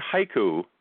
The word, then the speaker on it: haiku: The word haiku in English is two syllables. In Japan, it counts as three sounds, ha-i-ku.